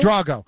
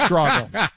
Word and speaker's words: Drago: Drago. Yeah, I can't believe you're asking me for a name pronunciation, especially for a European, but it's Drago.